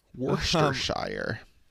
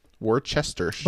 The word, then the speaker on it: Worcestershire: Um, Worcestershire. Worcestershire.